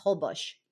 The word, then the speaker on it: Holbox: My last time, it's spelled Holbox, but it's pronounced Holbox.